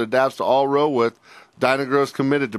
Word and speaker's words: Dyna-Grow: Give me a word that rhymes with toe. – Grow. – Dinah Grow. All right. – Dyna-Grow is growing in your fields.